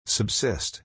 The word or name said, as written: subsist